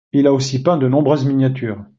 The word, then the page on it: miniatures: french